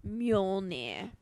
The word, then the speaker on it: Mjolnir: Yeah, I always say it wrong. Whatever. At I'm going to say it for everybody. Mjolnir.